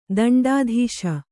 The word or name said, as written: ದಂಡದೋಸ